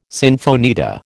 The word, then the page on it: sinfonietta: American English